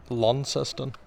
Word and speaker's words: Launceston: Launceston, just kind of like L-O-N, Lon, I guess.